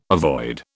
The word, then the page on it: avoid: British English